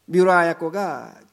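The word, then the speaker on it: 미우라 아야코 (三浦綾子): 제가 다시 한번 미우라 야고 얘기, 미우라 발음이 안 되는데 아야코 얘기를 하겠습니다. 미우라 아야코가 그 책을 읽었는데,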